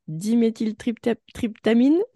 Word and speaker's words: diméthyltryptamine: Je voulais revenir, donc tu nous parlais de la, et donc attention, je vais écorcher son petit nom, de la diméthyltryptamine.